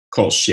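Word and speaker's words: Kölsch: That's how you say it, right? Correct? Kolsch? – Kolsch, yeah. Kolsch.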